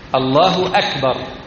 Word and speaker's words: أكبر: هذه الواو الضمة التي على الاسم الحسن، الله أكبر الله أكبر، وأكبر مبدوءة بماذا؟ بهمزة قطع أم ألف وصل؟ همزة قطع، إذن لابد أن تظهر. الله أكبر. أما إذا كانت مبدوءة بألف وصل الله أكبر، لأن ألف وصل حالة الوصل لا تنطق، لكن هذه همزة قطع لابد أن تنطق، الله أكبر.